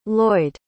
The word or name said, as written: loyd